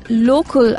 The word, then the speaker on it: Locul: New hostel, Local Uptown, which is spelled as L-O-C-U-L, Uptown, just opened in the posh part of town and channels the best of Bangalore and Wes Anderson.